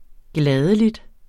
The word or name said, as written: gladelig